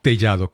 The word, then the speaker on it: Tellado: creo que el, apellido es Tallado. – Tellado, Corín.